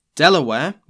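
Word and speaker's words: De La Warr: Reinforcements and supplies had arrived, with the governor himself, Thomas West, the 3rd Baron de la War. Delaware, which is how I'm going to be pronouncing his name, forced the survivors to turn around.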